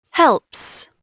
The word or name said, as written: helps